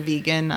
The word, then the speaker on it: Vega: I don't know how you pronounce that. – We'll it's say Vega because of vegan, I'm guessing. – Well, it's Vega because I'm Spanish. I'm Mexican.